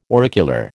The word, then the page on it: auricular: American English